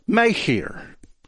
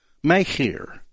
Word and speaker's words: Machir: And that's the way the name would be pronounced, Micah, which is similar to his host, Machir. Machir. It's the hard H sound, that C-H.